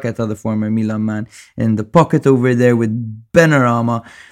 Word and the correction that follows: Benrahma: Bowen, playing off the shoulders of Antonio. Sorry, I fucking butchered that. Benrahma. Benrahma. – Benrahma, yeah.